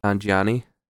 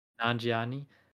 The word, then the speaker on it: Nanjiani: Nanjiani. – Nanjiani.